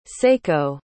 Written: seiko

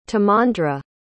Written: timandra

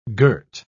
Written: girt

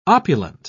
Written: opulent

